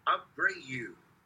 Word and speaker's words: Abreu: right fielder Bobby Abreu. Now, even though his last name consists of only five letters, it has three syllables, so I have some fun with that.